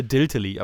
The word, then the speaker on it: DLTLLY: Habe ich es richtig ausgesprochen? Äh, Diltely, aber.